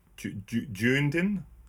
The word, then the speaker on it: Dunedin: Dunedin? Is that how you pronounce it? Dunedin? – Dunedin, yeah. Sweet. – Dunedin? – No, it's Dunedin.